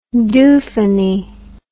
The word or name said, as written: dofunny